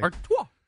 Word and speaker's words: Artois: Artois. – Can you spell it? – A-R-T-O-I-S.